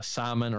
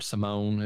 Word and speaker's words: Simone: Claudio Camusso playing Simon or Simone, as it looks how it's spelled, but Simon's how he's pronounced in the movie.